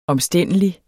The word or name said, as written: omstændelig